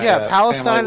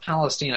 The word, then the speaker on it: Palestine: Yeah, it's pronounced Palestine. I've heard like both my grandparents who used to live there call it that, and I've heard Ron Paul mention it at one point and call it that. – Yeah. – For whatever reason, it's pronounced that way, even though the Middle Eastern area is pronounced differently.